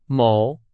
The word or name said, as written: 某